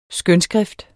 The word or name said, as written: skønskrift